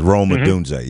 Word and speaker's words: Odunze: When we think 2 weeks out, do you think that, is it Roma Odunze? Is that how you say it? – Yeah, Roma Odunze. Yep.